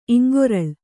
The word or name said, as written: ಇಂಗೊರಲ್